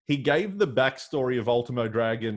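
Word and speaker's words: Último Dragón: And then he pronounced his name the proper Mexican way, Ultimo Dragon.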